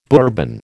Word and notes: Bourbon: American English

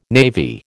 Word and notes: navy: American English

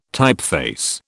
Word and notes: typeface: British English

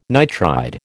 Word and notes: nitride: American English

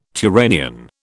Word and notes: Turanian: British English

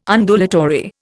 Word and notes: Undulatory: Indian

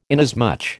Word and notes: inasmuch: American English

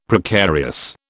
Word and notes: Precarious: British English

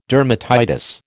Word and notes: dermatitis: American English

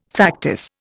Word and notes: factice: Indian

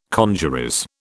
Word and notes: congeries: British English

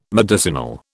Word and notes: medicinal: British English

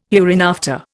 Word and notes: hereinafter: Indian

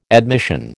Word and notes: admission: American English

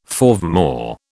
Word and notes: forevermore: British English

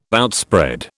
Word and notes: outspread: British English

